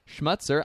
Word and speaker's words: Schmutzer: Hey everybody, we have Josh Schmutzer.